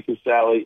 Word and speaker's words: Casale: Did they pronounce it Casal? – No. Listen, Bob won is my cousin, Bob Mothersbaugh. Bob two is Bob Casal. And Jerry Casale is.